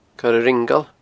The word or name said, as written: Coire Ringeal